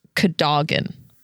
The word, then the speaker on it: Cadogan: So for our listeners, it's spelled C-A-D-O-G-A-N. The Harry Potter lexicon says that it should be pronounced Cadogan. – Cadogan. – Which is backed up by a deleted scene from the Prisoner of Azkaban movie. He pronounces his own name as Cadogan, Sir Cadogan. Almost like toboggan.